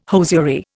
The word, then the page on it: hosiery: Indian